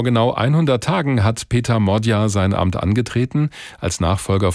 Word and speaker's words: Magyar: Der schreibt sich zwar Péter Magyar, also mit G und Y im Nachnamen, spricht sich aber Modja aus.